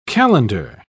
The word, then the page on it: calender: British English